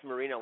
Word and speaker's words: Marino: Yes, Marino, like the Italian ice.